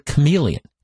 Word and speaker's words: chameleon: The word chameleon. That was the villain. It was chameleon. But chameleon is spelled like Shyamalan. C-H-A. And I remember I couldn't get it. I couldn't get that the H was silent.